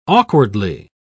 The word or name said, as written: awkwardly